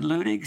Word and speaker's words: Leunig: spelled L-E-U-N-I-G, but I believe it's pronounced Leunig, sort of a popular figure of fresh ways of putting things.